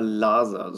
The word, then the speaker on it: Laser: Genauso ist es mal ein Interview mit äh, Dieter Laser. So spricht man seinen Nachnamen aus, ne? – Laser. Ja, ne? genau.